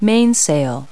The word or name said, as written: mainsail